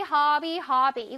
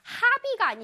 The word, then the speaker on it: hobby: Hobby, hobby, hobby. 이건 발음이 그렇게 어렵지 않아. 해볼게요. 하비가 아니라 얘들아, 하비가 아니라 턱을 아래로 내려야 돼요.